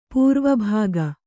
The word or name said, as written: ಪೂರ್ವಭಾಗ